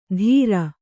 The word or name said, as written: ಧೀರ